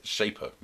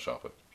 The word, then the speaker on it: Shaper: Like you put in your interest in what you're looking to network with, and then it brings up Shaper, not Sharper.